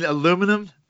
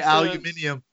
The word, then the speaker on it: aluminium: I think the true test of your conversion to Britishness is, how do you pronounce that lightweight alloy that they make planes and some cars out of? – Oh, you mean aluminum? – Look, I still can't say aluminium.